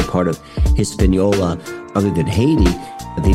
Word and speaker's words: Haiti: So that is, you know, the word Haiti. Haiti is phonetically the same. It's a French word, but it's phonetically the same as Hades.